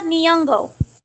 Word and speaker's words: Nyong'o: Lupita Nyong'o.